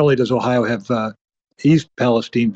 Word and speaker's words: East Palestine: But by, by the way, I'm pronouncing it East Palestine because that's how the way the locals do.